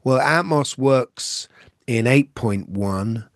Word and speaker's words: Atmos: Am I saying that right? Atmos? – Atmos, yeah.